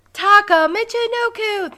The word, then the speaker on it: Taka Michinoku: Taka Michinoku. Thank you, Sonny, for pronouncing that correct.